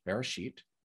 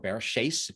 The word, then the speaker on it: Bereshit: What she saw is that all the other times in the Bible that that word was used, Bereshit, or Bereshis, if you use the Sephardic pronunciation, it always was used the same way.